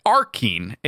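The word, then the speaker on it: achene: They kind of do, but technically the way that this works, Kenny, is that those little tiny seed-looking things are called arcene. I believe that's how you say it. Arcene. A-R-C-H-E-N-E. And there are about 200 of them on each strawberry.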